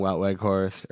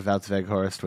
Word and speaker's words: Wout Weghorst: Depay, Bergwijn, Luuk de Jong, Wout Weghorst, or Wout Weghorst, whichever, however you want to pronounce it. English, like Americanized, it's Wout Weghorst, but Wout Weghorst sounds a lot better.